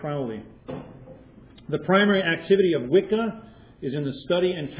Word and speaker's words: Wicca: Wicca, whose double C is pronounced in Old English as a soft CH, a CH, so thus Wicca, is the origin of the modern English word witch.